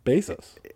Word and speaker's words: Bezos: I mean, how does he say it? – Bezos. – Okay, well then that's how you say it.